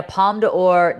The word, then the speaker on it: Palme d'Or: And in the years that followed, the Killdozer inspired punk song, conspiracy theories, and a Palme d'Or nominated foreign film.